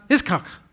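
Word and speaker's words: Yitzhak: Because in Hebrew, Isaac is pronounced Iskak.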